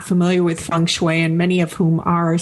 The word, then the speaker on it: Feng Shui: And for those who might want to know that specifically, it is two separate words, and it is spelled F-E-N-G, as in Feng, but it is pronounced Feng. And then the separate word is S-H-U-I, and that's pronounced Shui, Feng Shui.